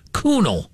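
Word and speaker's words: Kuhnel: K-U-H-N, like John Kuhn, but with an E-L on the end. It's pronounced Kunel.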